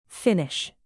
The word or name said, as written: finnish